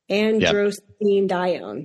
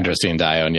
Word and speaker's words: androstenedione: A, endostrandione. I don't think I said that right. B, melatonin. C, aldosterone. D, cortisol. E, angiotensin II. Androstenedione. Yep. There you go. – Androstenedione, yeah, which is the answer here.